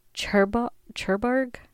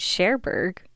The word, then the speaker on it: Cherbourg: She arrived in Cherbourg. Hold on, I gotta Google that. I was not pronouncing that right at all. It's Cherbourg.